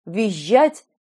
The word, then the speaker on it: визжать: Он произнес это с двойным мягким «ж» – «визжать» и «брюзжать».